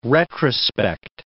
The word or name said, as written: retrospect